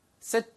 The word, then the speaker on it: sit: Sit back. 되게 간단하죠. 이것도 길게 발음하시면 안 돼요. Seat라고 하면은 걔는 S-E-A-T를 이야기합니다. 좌석 혹은 앉히다라는 뜻이에요. 그래서 얘는 sit, 짧게, sit back.